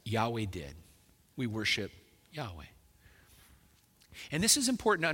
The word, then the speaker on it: Yahweh: Hebrew has no J sound, so I can just say clearly that's not the right pronunciation. That's just an Englishized version of it. Scholars, when they talk about this, there's kind of a general agreement that probably the most likely pronunciation is Yahweh.